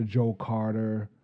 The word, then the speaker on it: Joe Carter: As a guy that knows about the Joe Carter era, I say that right? I better say that right. – You got it.